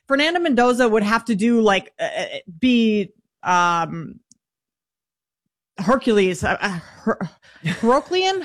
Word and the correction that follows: Herculean: How do you say that word? – Herculean. It would take a Herculean season.